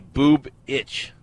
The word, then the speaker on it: Bubich: I say boob itch, like right. – The boob itches, like my boob itches.